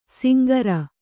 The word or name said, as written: ಶಾಂಕರ